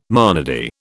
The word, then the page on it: monody: British English